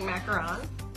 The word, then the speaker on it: macaron: And let's go through, we are not just being fancy by saying macaron. – Yeah, no, that's right. That's the accurate one. – We're not being trying to be bougie or anything like that. That's how you say it.